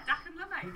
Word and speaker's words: Dakan Lumai: Dakan Lumai /Lost Heritage Project. Was that okay? – That was perfect. So Dakan Lumai, that's it.